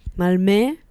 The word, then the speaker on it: Malmö: How do I pronounce it? With it's O with an umlaut. Malmö. And perform kat Malmö.